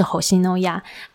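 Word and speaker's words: 虹夕諾雅: 红系洛亚，日文发音的名称就有点像啊，就直接是红系诺亚。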